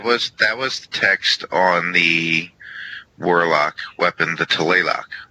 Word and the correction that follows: Tlaloc: Tlaloc. You put the emphasis on the wrong syllable. Tlaloc. Tlaloc.